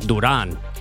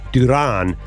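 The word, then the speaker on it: Durán: Like There's a tilde over the A. Duran. – Duran.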